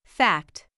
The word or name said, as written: fact